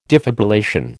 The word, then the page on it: defibrillation: American English